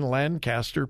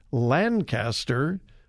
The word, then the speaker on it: Lancaster: And I have to continually catch myself as how to pronounce L-A-N-C-A-S-T-E-R. Because growing up in California, it was Lancaster. Here in central Ohio, it's Lancaster. California, Lancaster. Here in Ohio, Lancaster. In Pennsylvania, Lancaster, I think.